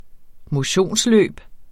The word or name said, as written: motionsløb